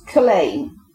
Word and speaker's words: Culzean: We've got Cockenny, which is spelled Cock E-N-Z-I-E, and Culain, C-U-L-Z-E-A-N.